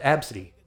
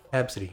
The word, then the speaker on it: Abcde: It's spelled A B C D E, is the kid's name. – What? – And they pronounce it Absidy. – What? – Yeah, Absidy.